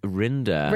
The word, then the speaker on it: Rinder: Yeah, and it literally shows, the only show that's on there is like Judge Reiner. – Oh, Judge Rinder. – Rinder, Reiner, Rimmer, whatever. – Thank you. Made it rhyme with grinder there.